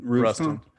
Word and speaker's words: Ruston: How do you say it? Ruston? – Ruston. Ruston. Ruston. Ruston. Don't make it too complicated.